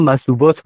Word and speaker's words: مصوبات: من فکر کنم مصوبات باشه ها تلفظش، جمع مصوبه.